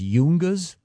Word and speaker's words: Yungas: Is it pronounced Yungas? – Yes.